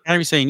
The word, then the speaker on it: Kairi Sane: Kairi Sane, yeah.